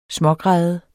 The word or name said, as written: smågræde